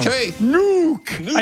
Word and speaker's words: Nuuk: Yes, N-U-U-K. Wow. – Nuke. I just phonetically spelled it. – Wow. That's right. – Oh, wow. Okay, I got three. – This is for Pepe for his O-A-A, old actress addiction.